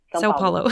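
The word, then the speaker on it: São Paulo: Did I say it right? – Sao Paulo. – Yeah. Okay, thank you. – Perfect.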